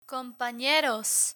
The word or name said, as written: companeros